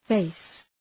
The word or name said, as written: face